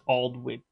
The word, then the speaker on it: Aldwych: Well, W-Y-C-H, it's just Aldwych.